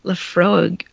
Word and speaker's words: Laphroaig: It's with an emphasis on the second syllable. – Laphroaig. Laphroaig? – Freud, like Froyg. – Yeah, A-I-G.